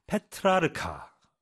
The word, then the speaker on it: 페트라르카: 이 O'Driscoll에 따르면 타로는 원래 bridge, 브릿지와 비슷한 카드 게임에 사용했지만 이때 게임의 개념은 부분적으로 이탈리아 르네상스 시인인 페트라크카의 시 승리, 뭐 triumphs에서 영감을 받았는데 이 작품은 도덕적 위계 질서를 배열한 14세기의 우아 시였습니다. 그렇죠? 페트라르카. 발음이 어렵네요.